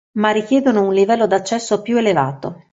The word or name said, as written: elevato